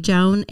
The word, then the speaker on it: Joann: Um, actually, it's Joanne, but somewhere along high school days, uh, friends shortened it, started calling me Joan, and that's how I introduced myself. So it's spelled Joanne, pronounced Joan, email is Joanne.